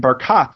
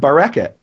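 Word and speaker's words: Barkat: But so the mayor of Jerusalem, he pronounces his name Barakat the way it's pronounced in Ezekiel, according to this ancient pronunciation tradition. Most people in Jerusalem that I know will call him Bereket. And why would they call him Bereket? They'll mispronounce his name.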